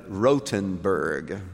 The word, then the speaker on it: Rothenburg: When we were in Germany, we visited Rothenburg, which is the correct pronunciation of that particular town, a medieval city that still has the walls.